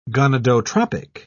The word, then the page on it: gonadotropic: British English